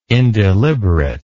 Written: indeliberate

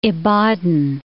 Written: Ibadan

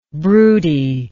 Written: broodiness

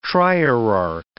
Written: trierarch

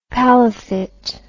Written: palafitte

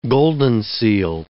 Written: goldenseal

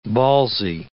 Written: ballsy

